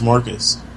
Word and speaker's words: Marcus: My name, sir, is Marcus. – Okay, can you spell that, please? – It's M-A-R. – Huh? – C-U-S.